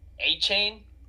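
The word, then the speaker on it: Achane: You know, Devon Akane. – He's not on mine. But I definitely see why he's on yours. I like him a lot. I think it's pronounced A-Chain.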